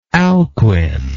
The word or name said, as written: Alchuine